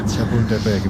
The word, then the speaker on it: Chapultepec: Chapultepec. Bien, mejor que algún cementerio sí, francés. – Chapultepec, bien.